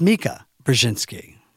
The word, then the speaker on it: Brzezinski: Again on Morning Joe, it's Mika Brzezinski.